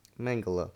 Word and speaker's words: Mengele: Mengele, who would poison kids and pregnant women and then see how the poison went through their systems.